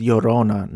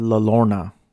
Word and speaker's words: Llorona: It kind of reminds me of the Llorona, which, by the way, it's pronounced Llorona, not La Llorona, Pat and Joe, as if you're listening and supporting me in my personal endeavors.